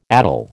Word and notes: addle: American English

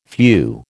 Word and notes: phew: American English

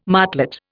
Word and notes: martlet: Indian

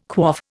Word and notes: quaff: Indian

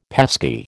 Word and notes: pesky: American English

